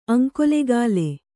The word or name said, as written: ಅಂಕೋಲೆಗಾಲೆ